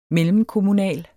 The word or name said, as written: mellemkommunal